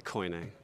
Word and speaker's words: koine: Kini Greek? – Yeah, koine. I call it koine.